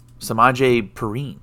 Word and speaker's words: Samaje Perine: um, so next bit is your boy, Samaje Perine.